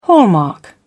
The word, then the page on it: hallmark: British English